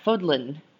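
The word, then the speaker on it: Fódlan: But Fodlin, yeah, Fodlin. That's kind of fun to say. – It rhymes with yodlin.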